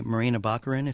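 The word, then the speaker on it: Morena Baccarin: And it is during the scenes where Brody is with his wife, played by Marina Baccarin, if I'm pronouncing her name correctly.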